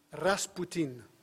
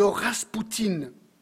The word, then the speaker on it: Raspoutine: Grigori Lefimovitch Novik Raspoutine. Alors, ça c'est le nom complet du gars. En fait, son nom, il est connu comme Raspoutine. Peut-être avez-vous déjà entendu du mot ou du nom de Raspoutine.